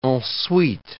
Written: en suite

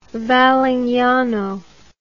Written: Valignano

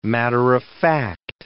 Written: matter-of-fact

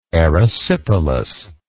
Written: erysipelatous

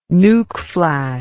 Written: nucflash

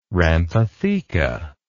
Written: rhamphotheca

